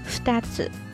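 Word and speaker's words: ふたつ: 而数字二同样也有音读和训读之分，分别读作你和 ftats。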